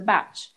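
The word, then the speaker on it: Bach: Well, first of all, because otherwise I'll get kind of told off, but it's actually, it's Dr. Batch. And it's actually pronounced the English way. And I've actually, before I used to call it Dr. Batch as well, but it's Dr. Batch.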